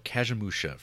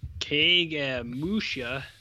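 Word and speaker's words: Kagemusha: Let me look up Kajimusha tagline. That's how you pronounce that, right? – It's like cage match.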